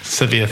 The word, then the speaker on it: Sevilla: And just to remind the listeners, that those that don't know, obviously Juventus, Seville and Borussia Mönchengladbach, and that's probably not been pronounced very well Sevilla. – Either. Sevilla, thank you very much.